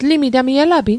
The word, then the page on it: elapit: Mi'gmaq